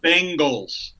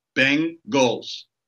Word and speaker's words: Bengals: Bengals. B-E-N. Bengals. – That's not how it's spelled. It's B-I-N-G-L-E-S.